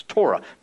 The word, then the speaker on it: Torah: Actually, Torah is how you say the Hebrew word. It's Torah, means law.